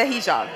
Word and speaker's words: hijab: am I saying it right? The hijab? – Yes, the hijab.